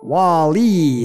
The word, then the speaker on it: Wally: You said Wally. – Wally. – Accent on the second syllable.